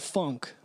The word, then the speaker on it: phonk: So it's called funk, but it's spelled P-H-O-N-K.